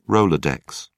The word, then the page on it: rolodex: British English